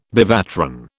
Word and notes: bevatron: British English

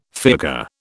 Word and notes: fiacre: British English

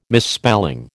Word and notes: misspelling: American English

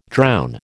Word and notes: drown: American English

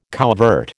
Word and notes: couvert: American English

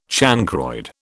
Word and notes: chancroid: British English

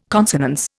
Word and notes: consonance: Indian

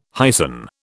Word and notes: hyson: British English

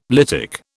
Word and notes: lytic: British English

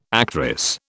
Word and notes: actress: British English

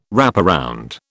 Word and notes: wraparound: British English

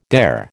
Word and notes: dare: American English

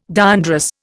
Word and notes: diandrous: Indian

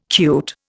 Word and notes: cute: Indian

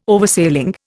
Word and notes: Oversailing: Indian

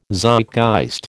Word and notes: Zeitgeist: American English

